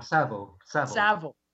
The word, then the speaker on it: Savile: When Seville was exposed, and am I still pronouncing that wrong? Is that you're still pronouncing it wrong? Savile.